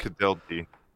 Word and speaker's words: Winkeltje: And Winkelt, the little shop. – Winkelty. – it's spelled Winkeltje.